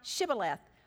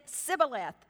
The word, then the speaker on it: Shibboleth: If he said no, then they would say to him, "Say now Shibboleth." But he said, "Sibboleth," for he cannot pronounce it correctly.